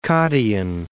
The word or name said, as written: Cottian Alps